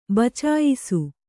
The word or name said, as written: ಬಚಾಯಿಸು